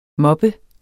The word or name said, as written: moppe